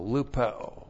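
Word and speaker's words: lupeo (λυπέω): L-U-P-E long O, lupeo.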